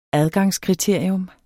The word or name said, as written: adgangskriterium